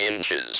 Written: inches

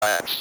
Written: glass

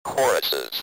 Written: choruses

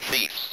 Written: thief's

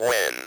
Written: when

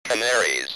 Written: canaries

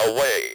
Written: away

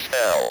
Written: tell

